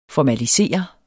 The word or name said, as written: formalisere